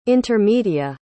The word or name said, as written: intermedia